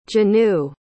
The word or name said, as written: jenue